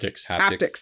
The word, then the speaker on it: haptics: No, this one is actually, it was designed for the hepatics. Okay. Hepatics? Did I say that right? – Haptics. – Thank you.